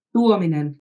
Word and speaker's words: Tuominen: Tuominen.